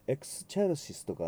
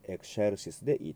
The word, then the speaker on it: excelsis: CE の前に X がついてますので、エクシェーと発音します。外国の国によっては、エクスチェルシスとか、そういうふうな発音でされる場合もありますが、今回はカトリックラテンということで、エクシェルシスでいいと思います。